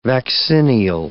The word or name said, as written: vaccinial